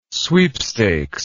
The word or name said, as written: sweepstakes